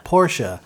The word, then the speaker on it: Porsche: Or how do you say it? Porsche? – A Porsche.